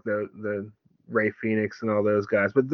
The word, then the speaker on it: Rey Fénix: It's pronounced Rey Fénix. Yeah. You say it like Excalibur says it. Rey Fénix.